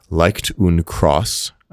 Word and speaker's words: Leicht und Kross: I believe it's pronounced Leicht und Cross, L-E-I-C-H-T U-N-D K-R-O-S-S.